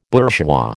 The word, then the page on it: bourgeois: American English